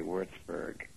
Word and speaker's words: Würzburg: And in English, you'd say Würzburg. The other's a German pronunciation.